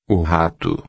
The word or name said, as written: rato